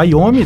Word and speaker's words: Iommi: Vamos abrir com Immortal, música em parceria com Tony Iommi, ou Iommi, dependendo do pronunciante.